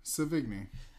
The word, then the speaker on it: Sevigny: How would you pronounce that? – Sevigny.